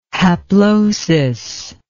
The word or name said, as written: haplosis